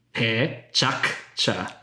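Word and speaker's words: PechaKucha: But the way that I'm supposed to say it as an organizer is the Japanese way of saying it, which is essentially three syllables, but you have a hard k to sort of have that U after it kind of come out. So it's pe chak cha.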